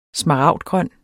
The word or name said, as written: smaragdgrøn